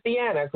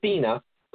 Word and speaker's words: Deanna: That's Open Box, B-O-F-E-D, Poetry by Dina Culver. Now, you may see it as Deanna because it's D-E-A-N-N-A, but it's pronounced Dina Culver.